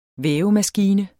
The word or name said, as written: vævemaskine